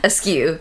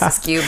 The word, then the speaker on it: Askew: Thanks for saying my name correctly. – What do people say? – Askew. – Askew? – Which I guess will make my name an oxymoron. Sorry, it's Grace Askew, but...